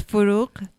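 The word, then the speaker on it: Forugh: it's Furuk Farrokhzad. – Farouk. So there's it almost sounds like a K on the end.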